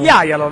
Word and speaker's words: Jajalo: Sentiamo, ho trovato un sito che fa la pronuncia. – Mato Jajalo. – Jajalo, vedi, ecco, allora avevo ragione, Jajalo. La seconda supposizione è stata corretta, va bene, buono sapersi, quindi lo chiameremo Jajalo per il resto dell'incontro.